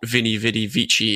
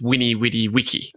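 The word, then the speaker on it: veni vidi vici: Like, well, just to go totally left field, Julius Caesar was not Vinny Vidi Vici, it was Winny Widi Wiki. – right, just as how they would pronounce classical Latin, but that's a whole other track.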